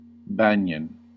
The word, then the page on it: banyan: American English